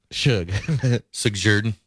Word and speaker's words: Shug Jordan: You wouldn't necessarily think this, but Gus was fifth on Auburn's all time win list behind Shug Jordan, Mike. Is that I say it right? – Shug Jordan, Mike Donahue, Pat Dye, and Tommy Tuberville, Senator Tuberville.